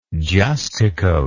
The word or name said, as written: justicoat